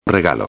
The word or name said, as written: regalo